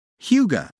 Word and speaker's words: hygge: Hygge, pronounced hygge, is a word that describes a cozy, contented mood evoked by comfort and conviviality.